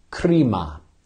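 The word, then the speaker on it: krima (κρίμα): Interestingly enough, that word damnation, the Greek word that gives us that is krima, krima, krima, like we would say C-R-I-M-E, crime, right? We pronounce it different, but it's the word for crime.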